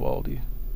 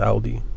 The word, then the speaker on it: Aldi: Love Aldi. Some people pronounce it Aldi. I believe it's Aldi. I've always pronounced it Aldi.